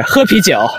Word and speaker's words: 喝啤酒: 喝啤酒.